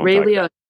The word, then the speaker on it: Ray Liotta: You know, Ray Liotta.